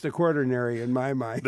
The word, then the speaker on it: Quaternary: I want to say quaternary, but it's not. – Quaternary. – Quaternary geologists. Quaternary. I got to get that right.